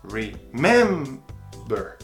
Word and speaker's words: remember: Por mais que termine em CVC, a sílaba tônica é no mem. Remember.